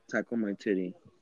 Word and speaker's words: Taika Waititi: Because Taika Waititi, is that his name right? Mm-hmm. I can't say his name. The way he directed the movie. – Yeah, his name is Taika Waititi. – Dude, that's how you pronounce that, bro.